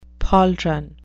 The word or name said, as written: pauldron